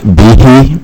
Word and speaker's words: Bihi: Chair, members, my name is Abdul Rizak Bihi.